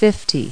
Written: fifty

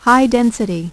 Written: high-density

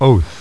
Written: oath

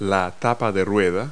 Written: hubcap

